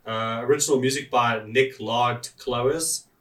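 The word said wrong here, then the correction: Nick Laird-Clowes: Wow. – Is that how you pronounce his name? – Nick Laird Clowes.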